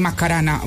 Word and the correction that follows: Maracaná: ¿Cómo se dice? – Maracaná.